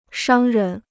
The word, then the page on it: 商人: shāng rén